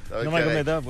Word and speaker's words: Nurmagomedov: Nurmagomedov, c'est ça? Nurmagomedov? – Oui. – Khabib. – Il n'y a pas plus de syllabes? – Nurmagomedov, oui.